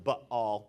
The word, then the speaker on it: Baal: Some call it Baal, but it's actually pronounced Baal, B-A-A-L. So Baal I would prefer. If you say Baal, I'm going to bail on you.